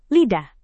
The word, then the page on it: lidah: indonesian